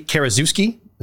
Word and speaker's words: Karaszewski: And the screenplay is written by Scott Alexander, and I hope I'm pronouncing his last name right, Larry Karaszewski, is that right? – That's it.